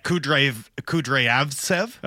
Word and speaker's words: Kudryavtsev: I've gotten in the habit of really pronouncing the first V in his name. – Okay. – Kudryavtsev.